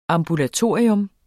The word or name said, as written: AMBULATORIET